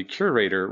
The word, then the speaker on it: Curaytor: You started your last company, your most current company, Curaytor, rhymes with Gator.